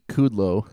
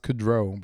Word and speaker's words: Kudrow: I think it's actually pronounced Kudrow, but. – Oh, but yeah, that's right. Her name is Kudrow, not Kudlow.